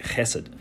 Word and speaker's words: chesed: So we pick up in, and let me say this too, that word steadfast love is the Hebrew word chesed. You kind of got a little phlegm in the back of your throat there to say it right, chesed.